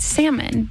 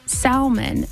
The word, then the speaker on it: salmon: But then I got to thinking about just the word and how we say it, because I've always said salmon, but a lot of people will say salmon, and I don't know how you say it, and I don't even know which way is right. I know that there is an L in there, but my whole life I've just heard it and said it salmon, not salmon.